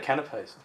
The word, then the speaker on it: canapé: How do you say that word? Is it canapé? – Canapé. – Canapé, yeah.